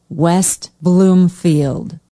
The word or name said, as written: west bloomfield